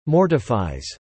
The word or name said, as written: mortifies